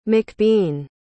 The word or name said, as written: mcbean